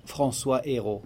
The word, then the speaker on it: François Héro: Uttala det ordentligt på franska. François Héro. Ja. På svenska blir det så. – Ja, hur säger man på franska? – François Héro.